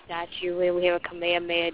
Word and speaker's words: Kamehameha: No, you know, there's a road sign here that's spelled just like that, like Ka-me. Ha, me, ha. You know what that means here? – You're in Hawaii, though. – Yeah, but you know what that means? It means Kamehameha. It's pronounced Kamehameha.